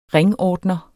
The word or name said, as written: ringordner